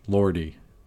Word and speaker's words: Lordi: Lordi, L O R D I, not to be confused with the. Uh, modern Chantus Lorde, who you could actually pronounce it the same way.